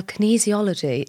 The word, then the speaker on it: kinesiology: Well, kinesiology is... – Have I said it right? – You have definitely said it right, yes.